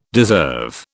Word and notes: deserve: British English

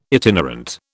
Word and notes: itinerant: British English

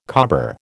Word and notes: cobber: American English

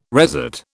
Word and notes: resat: British English

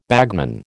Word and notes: bagman: American English

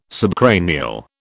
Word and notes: subcranial: British English

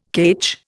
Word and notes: ketch: Indian

